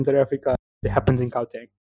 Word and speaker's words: Gauteng: Gauteng. – Gauteng.